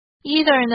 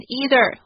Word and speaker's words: either: either 呢可以念成 either 或是 either